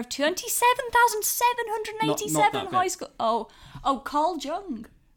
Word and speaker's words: Carl Jung: Carl Jung, I think it's pronounced. – Yes.